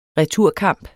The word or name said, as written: returkamp